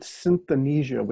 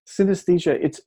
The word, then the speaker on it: synesthesia: I used to pronounce it synthesia, which sounds really cool, and maybe my next book will be called that. But synesthesia, it's essentially a melding of your senses.